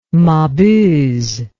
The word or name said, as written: mabuse